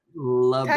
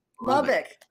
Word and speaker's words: Lubbock: Lubbock. – Texas? Lubbock.